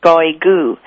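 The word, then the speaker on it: Cáuigú: For instance, we're actually Goigu is how you say it in Kiowa, but because they couldn't pronounce that, they just stopped with Kiowa.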